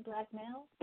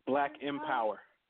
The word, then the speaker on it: BLKMPWR: Is it Black, how do you pronounce it? Black Male? – Black Empower. – Black Empower, right.